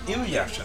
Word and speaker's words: Inuyasha: Inuyasha, né? – Inuyasha.